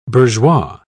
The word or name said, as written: bourgeois